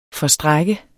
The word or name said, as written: FORSTRAKT